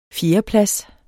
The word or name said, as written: fjerdeplads